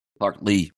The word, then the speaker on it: Clark Lea: Vanderbilt, I mean, it wasn't a must-win game for Vanderbilt's coach, Clark Lea, or how do you pronounce his name? – Clark Lea. – Clark Lea.